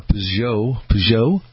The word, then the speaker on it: Peugeot: I don't know what a pug looks like a Peugeot 504. Am I saying that right? Peugeot. That's how we say it.